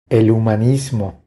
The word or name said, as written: El humanismo